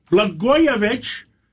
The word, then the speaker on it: Blagojevich: Well, Blagojevich rhymes with Katzen Matidis.